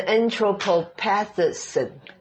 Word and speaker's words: anthropopathism: 好，这个字是念成 anthropopathism。